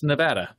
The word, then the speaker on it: Nevada: Apparently, it's Nevada. That's the preferred pronunciation of that state.